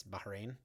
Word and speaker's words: Bahrain: Now, I always want to call mm-hmm. it Bahrain, but it's Bahrain.